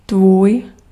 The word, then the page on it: yours: American English; [jʊɹz]; RP; /jɔː(ɹ)z/; /jɚz/; /jɝːz/; /jɔɹz/; /jɔːz/